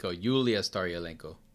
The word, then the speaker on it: Staryalenko: Julia Staryalenko. – You're right. That is actually how you say it in Russian.